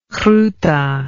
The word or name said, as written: groete